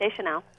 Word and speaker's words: Deschanel: On TV, Emily Deschanel, how do you pronounce her name? – That's correct, Deschanel.